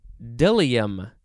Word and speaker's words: bdellium: Now we have an interesting word, It is dilium. I think that's how it's pronounced, but it is spelled B-D-E-L-I-U-M. Dilium, sort of. The B is silent.